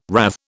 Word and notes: rathe: British English

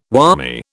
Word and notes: whammy: British English

